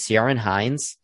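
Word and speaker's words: Ciarán Hinds: Let's do how do you say his name? Is it Ciaran Hinds? – Yeah, for Belfast, Troy Kotsur for Coda, Jesse Plemons for The Power of the Dog, J.K.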